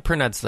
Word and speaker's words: Pernod: It's supposed to be absinthe or some form of Pernod. I don't know how you pronounce that properly. – Yeah, Pernod's how you say that.